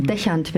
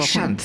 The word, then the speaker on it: Deschant: Deschant wird das ausgesprochen. Deschant. Sieht französisch aus, ist es aber nicht.